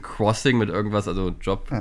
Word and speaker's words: Xing: Ja, das hat jetzt mit dem Jobwechsel ein bisschen abgenommen ähm, und auch auf so diesen Berufsplattformen wie LinkedIn und Xing oder Crossing, wie ich letztens erfahren habe, wie man das eigentlich ausspricht, äh, heißt ich nicht Hummel und da ist auch kein Hinweis drauf.